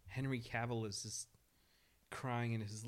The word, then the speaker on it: Cavill: um it's pronounced Cavill.